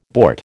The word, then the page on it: bort: American English